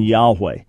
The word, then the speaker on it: Yahweh: But that just means the original pronunciation most likely would have been Yahweh, which is what most scholars believe and what I personally believe.